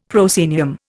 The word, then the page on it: proscenium: Indian